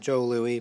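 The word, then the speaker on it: Jos Louis: It's J-O-S-L-O-U-I-S. So it's, well, they pronounce it Joe Louie, but it's Joss Lewis.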